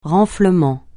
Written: renflement

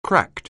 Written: cracked

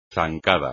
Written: zancada

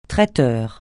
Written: traiteur

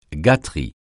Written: gâterie

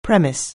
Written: premise

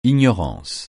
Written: ignorance

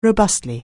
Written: robustly

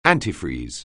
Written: antifreeze

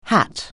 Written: hat

